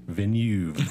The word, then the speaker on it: Villeneuve: Villeneuve.